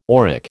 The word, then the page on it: auric: American English